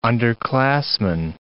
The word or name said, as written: underclassman